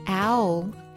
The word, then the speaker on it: owl: 首先呢，owl 是猫头鹰的意思，owl，那它发音呢，最后也有一个 l 的音，owl，注意不要发成阿呜哈呜结尾，不是这样的。